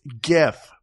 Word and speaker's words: GIF: It's GIF. – That's right. It's GIF, people. Steve Wilhite, I appreciate you for inventing this, but you're pronouncing it wrong. It's called a GIF.